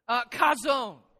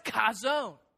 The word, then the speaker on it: chazon: And if you look at that word, it starts with a CH, and it's not pronounced chuh. There's no chuh sound in the Hebrew. And so it is pronounced chazon. And if you wanted to learn how to pronounce it, it'd be like trying to get ready for a good spit, right?